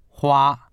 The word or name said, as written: hua1